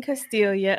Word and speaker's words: Castiglia: And so this is Robin Castella. Did I say that right? Castiglia. – Robin Castiglia. – Robin Castiglia of Ariel Tree of Life.